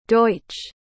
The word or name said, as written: Deutsch